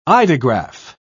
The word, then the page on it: eidograph: British English